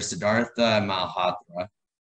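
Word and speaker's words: Sidharth Malhotra: So we have this character named Ayan, played by actor Siddhartha Malhotra. Is that the correct pronunciation, Mira? This pronunciation check, I get the thumbs up.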